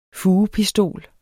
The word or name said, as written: fugepistol